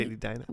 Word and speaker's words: Edina: Edina? I'm from here. You say Edina.